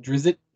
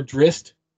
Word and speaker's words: Drizzt: Me personally, I pronounce it Drizzt or Drist. There's actually three, but Drist, you pronounced it Drist, which is fine.